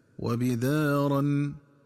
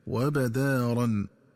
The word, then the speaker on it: وبدارا: وكذلك ينبغي التنبه للنطق الصحيح لكلمة وبدارا، فتقرأ بكسر الباء هكذا وبدارا، ومن الخطأ قراءتها بفتح الباء هكذا وبدارا.